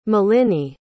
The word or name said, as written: maliny